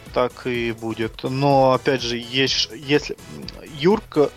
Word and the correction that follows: Юрчо: Юрчо, да, все-таки правильно Юрчо, конечно, произносить, это игрок э, очень силового плана, продавливающий.